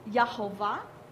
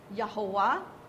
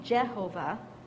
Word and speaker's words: Yahweh: We pronounce that Yahovah, Yahuwah, Yahweh. Those are different ways. Yahuwah. Different ways of pronouncing the Hebrew. But it's basically Yod, hey, vav, hey. And remember, there's no J in Hebrew. So it wouldn't be Jehovah.